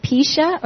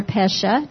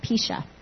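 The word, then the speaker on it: pisha (פשע): It's either pronounced pisha or pesha, depending upon if you're in the South or in England or wherever you are. I'm going to pronounce it as pisha. P-E-S-H-A, pisha.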